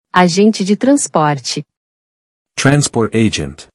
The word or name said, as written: Agente de transporte